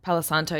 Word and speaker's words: Palo Santo: Palo Santo, yeah.